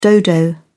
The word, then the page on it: dodo: British English